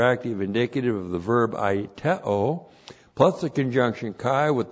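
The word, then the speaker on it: Aiteo (αἰτέω): A-I-T-E long O. Aiteo.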